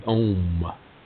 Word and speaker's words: Om: And Om is an interesting word, whereas it's a simple syllable, O-M, it has three distinct vibrations, O-M, O-M, A-O-M. It starts in the nasal cavity and ends in the chest cavity, O-M.